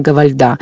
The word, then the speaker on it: Гавальда: Кто-то делает ударение Гавальда, кто-то Гавальда, но все-таки, поскольку она француженка, то чаще мы слышим Гавальда. – Считается на последний слог. – Да, на последний слог. Анна Гавальда.